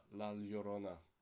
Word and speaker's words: La Llorona: Sorry, The Curse of La Llorona. Yeah, that's how you say it. Llorona, right? Yeah, La Llorona.